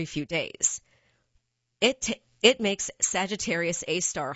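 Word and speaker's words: Sagittarius A*: The Global Array's other main target is the black hole at the center of the Milky Way, Sagittarius A, pronounced A-star.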